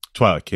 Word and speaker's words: Twi'lek: Twi'lek? – Twi'lek, yes.